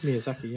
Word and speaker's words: Miyazaki: So I quickly read before watching this that the director Hayao Miyazaki, Did I say that right? – Yeah. I think it's Miyazaki, yeah.